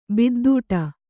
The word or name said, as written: ಬಿದ್ದೂಟ